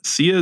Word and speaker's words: Sia: Sia, is that how you pronounce it? – Yep. – Yep. – Yeah, that's how I would say it.